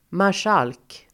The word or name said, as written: marskalk